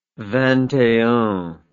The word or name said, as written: vingt et un